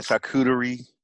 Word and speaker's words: charcuterie: Let me make sure I say this correctly because I kept fucking it up. We had our charcuterie. – Yes, bitch, that's how you say it. – We had our charcuterie, but I kept saying charcuterie.